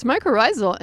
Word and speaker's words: mycorrhizal: The mycorrhizal. How do I pronounce it? – It's mycorrhizal, and they actually are in the roots.